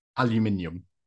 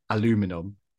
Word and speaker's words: aluminium: Aluminium. – Aluminium. Which I had to say it like that because for our American fans, I keep now saying aluminum.